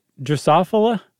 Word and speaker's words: Drosophila: Drosophila? – That's what I said. – It's much more beautiful than what I said, so I'm going to go with that, too. You know, most people say Drosophila.